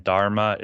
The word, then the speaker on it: Dharma: Okay, yeah, Dhamma is the Pali pronunciation of it and the Pali spelling, and Dharma is the Sanskrit.